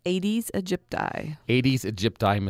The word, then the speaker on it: Aedes aegypti: Aedes aegypti mosquitoes, which is one of the carriers for Zika? – Currently, that is the main vector, okay.